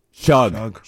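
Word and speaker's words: Shough: I have to apologize to him because I can never say his name right. – Tyler Shug? – Shug. That's what it is.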